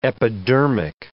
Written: epidermic

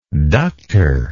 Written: ductor

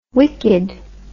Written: wikkid

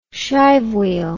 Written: shive wheel

